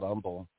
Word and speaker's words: Humble: There's this down here in Texas, there's a little town outside of Houston called Humble. It looks like Humble, but the H is silent.